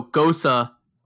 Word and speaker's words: Gose: It is a type of beer spelled G-O-S-E, but pronounced like the soccer player Mario Götze. It's like Gosa.